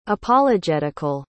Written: apologetical

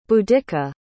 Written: Boudicca

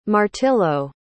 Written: martillo

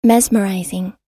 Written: mesmerizing

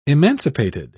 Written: emancipated

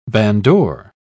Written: bandore

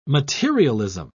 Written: materialism

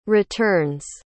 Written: returns

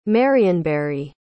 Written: marionberry

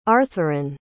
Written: arthurine

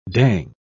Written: dang

